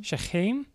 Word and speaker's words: Shechem: Shechem, son of Hamor.